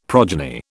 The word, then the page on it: progeny: British English